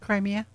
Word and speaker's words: Crimea: I never say that right. – Crimea. – Yeah, thank you.